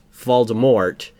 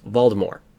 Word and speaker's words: Voldemort: Well, technically, it's not supposed to be pronounced Voldemort. It's supposed to be pronounced Voldemort. You don't pronounce the T, from it's what French.